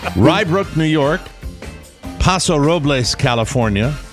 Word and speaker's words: Robles: Did I say that right? You gave me a pronouncer. – Robiz. – Robles. – What is it? – Robles. – Robles? – The Americanization Robles. Is Robles. – Robles. I don't need a pronouncer for Robles. – Okay. – Then. Because it's not Robiz. – Robles. – Robles. – Paso Robles. Paso Robles. Oh, it's BLZ, not BIZ. – Yeah, BLZ. Robles.